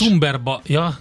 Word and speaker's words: Cumberbatch: Cumberbatch. – Cumberbatch, igen.